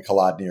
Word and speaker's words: Kolodny: Did I get that right? Kolodny? Am I saying that correctly? – Yeah, that's my last name and it's what I go by.